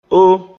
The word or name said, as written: Ó